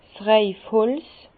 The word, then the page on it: 's Reifholz: Bas-Rhin; Herrlisheim